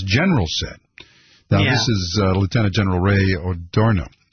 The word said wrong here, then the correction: Odierno: I'm having more. Why am I having so much trouble with pronunciation? You know, here's a confession. I know you're never supposed to confess publicly, but I have always had trouble with pronouncing really? – Names. Yeah. – Oh, I know that. I've been correcting you for 10 years. – Odierno. Odierno.